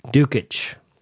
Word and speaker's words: Djukich: The author is Dusan du- Djukich.